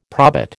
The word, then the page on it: probit: American English